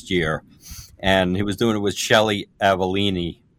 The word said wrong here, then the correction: Avellini: Well, they mispronounced her name, so she's Shelly Avellini.